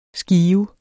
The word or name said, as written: skive